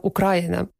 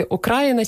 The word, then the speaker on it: Україна: Тут теж ця назва пройшла шлях розвитку від наголосу Україна, який потім перемістився відповідно до інших, за прикладом інших просторових назв, скажімо, Буковина. Наголос переміщується. І так само і Україна.